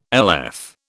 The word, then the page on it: LF: British English